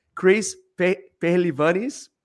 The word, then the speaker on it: Chris Pehlivanis: Is Chris Pehlivanis.